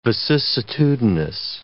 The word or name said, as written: vicissitudinous